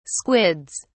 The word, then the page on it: squids: British English